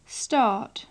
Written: start

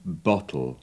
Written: bottle

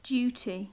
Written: duty

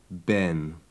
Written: ben

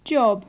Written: job

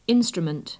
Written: instrument

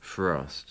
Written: frost

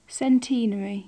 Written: centenary

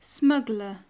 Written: smuggler

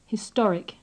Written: historic

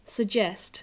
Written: suggest

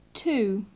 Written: too

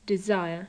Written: desire

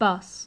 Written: bus

